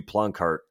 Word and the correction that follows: Poloncarz: said, is that how you pronounce his name? – Poloncarz. – Poloncarz, wow.